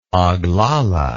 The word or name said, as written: oglala